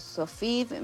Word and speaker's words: Sophiv: Bueno, esta canción la pueden buscar por Sofiv Melgot, que Sofiv se escribe S-O-P-H-I-V corta, Melgot, Mel, como se lo escuchan, G-O-D, y Nuevo Despertar.